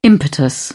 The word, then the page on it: impetus: British English